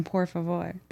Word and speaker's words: por favor: You don't roll your R's in por favor. – Por favor. – Yeah, they don't roll them. It's only certain words.